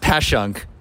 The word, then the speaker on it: Passyunk: It's pronounced Passyunk, but for those looking up the website, I'm saying Passyunk.